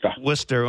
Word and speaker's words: Worcester: Yeah, it took me quite a while to learn how to pronounce that Worcester.